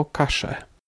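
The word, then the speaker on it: calle: Y, tam literę y oraz podwójne l wymawia się jako szy. Tak więc ja wymawia się szo, a ulice y, w argentyńskim hiszpańskim jako kasze.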